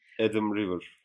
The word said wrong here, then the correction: Adam Driver: Bir kere Ha e, Driver evet Adam Driver doğru.